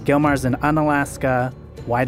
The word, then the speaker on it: Unalaska: Unalaska.